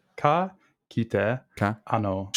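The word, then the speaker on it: Ka kite anō: Did I say it right? – Yeah, pretty much. – Okay, tell me how it's actually said. – Well, it's just phonetic, right? – Listeners, email me in how to pronounce Chow Chow's name. I still don't know. – No. Ka kite ano.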